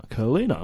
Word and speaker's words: Kalina: Yeah, anyway, so SBS, they pronounce him Chilena. Yeah. And then you go to Fox Sports and they go Curlina. And so, we're thinking, which one is it? And then it dawned on us, we've got the FFA pronunciation guide. So have a look. So we go through the Gold Coast list, and we go. Jason Kalina, and it's Kalina. – I'm like, okay, well that settles it.